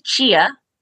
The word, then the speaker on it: Ch-a: So my name is Chia. That's the first thing. It's spelled C-H-A.